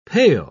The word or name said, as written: pail